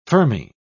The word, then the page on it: fermi: British English